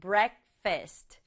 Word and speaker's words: breakfast: 아침 식사는 breakfast. Breakfast 라고 해요. 엄청 긴데요. 친구들 이 단어는 두 박자로 발음해요. Breakfast. Breakfast. 이렇게 앞에 강조줘서 breakfast. 두 번째는 점심 식사.